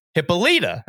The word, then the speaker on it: Hippolyta: So when I heard whatever I was listening to, it was one of the animated movies or whatever, they said Hippalta, So I'm like, that must be how you say it because they wouldn't say it wrong, right? Oh, Surely. And then everybody at my local store, uh, the, I'm, um, I don't know how much I'm going to call them out on this. You know who you are. You said it's pronounced Hippolyta.